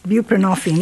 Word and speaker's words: buprenorphine: What about Chuck's point of view, and what about the underuse of, and I don't know if I'm pronouncing it correctly, buprenorphine? – Yes, buprenorphine, yes.